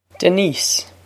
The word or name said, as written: Denise